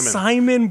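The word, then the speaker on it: Psimon: And a character whom I've never met before, but whose name is the best name I've ever read, Simon. Simon, but with a silent P.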